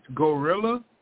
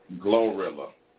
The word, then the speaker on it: Glorilla: Gorilla. – Glorilla.